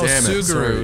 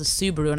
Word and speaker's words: Subaru: It's called a Subaru, and I wouldn't mispronounce things around my friend.